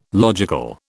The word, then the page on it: logical: British English